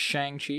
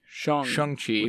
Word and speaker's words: Shang-Chi: I think that's, we've determined, we started by saying just Shang-Chi, because that's obviously kind of how it's written phonetically if you read it in English. And then we heard and corrected ourselves on the podcast to be Shang-Chi. With a U sound.